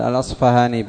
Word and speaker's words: الأصفهاني: قال الراغب الأصفهاني، يقال الأصفهاني بالفاء والأصبهاني بالباء، وجهان صحيحان.